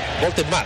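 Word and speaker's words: Woltemade: Es grandote este 11, Voltemaden. ¿Cómo es el nombre? A ver. – Voltemade. Así se pronuncia en español.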